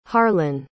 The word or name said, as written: harlan